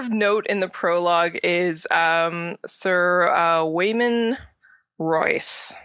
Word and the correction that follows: Waymar Royce: My yeah, Waymar Royce is how that's pronounced.